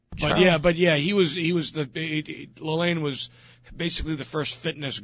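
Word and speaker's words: Lalanne: I just pronounced his name Jack Lalonde. Is that Lalane or Lalonde, the great I always said it was Lalane. – Lalane. – Lalane, yeah.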